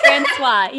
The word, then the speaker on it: Francois: Francois.